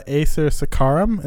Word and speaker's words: Acer saccharum: So Acer, how do you say it again? – Acer saccharum is how I would say it.